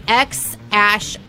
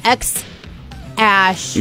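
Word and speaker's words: X Æ: So they, A-E, its pronunciation is Ash. So it would be X Ash Archangel would be his name, is how you pronounce it. X Ash.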